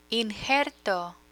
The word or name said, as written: injerto